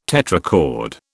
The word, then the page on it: tetrachord: British English